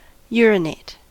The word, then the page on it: urinate: American English